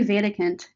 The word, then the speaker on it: Wedekind: that's how I would have said it before I moved here, but it's actually Vatikant. – Wedekind. Okay. – Yeah, it's very German. It's very German. – I should have known. I knew that German W's were pronounced like a V, but my bad.